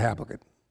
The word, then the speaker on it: Habakkuk: Habakkuk, and you can pronounce it all different kinds of ways, but I'm going Habakkuk.